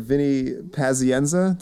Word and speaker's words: Pazienza: So Bleed for This is the story of Vinny Pazienza.